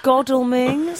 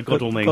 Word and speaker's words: Godalming: Excuse me, guys. It's Godalming. – Sorry, Godalming. – Godalming.